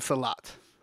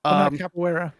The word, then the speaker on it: Capoeira: And Salat. – Capoeira.